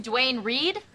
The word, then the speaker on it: Duane Reade: You mean Dwayne Reed? – Is that how you pronounce it? – Well, we're here.